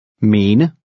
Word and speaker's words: mene: Mene. Mene. – Notice how lightly the last E is pronounced.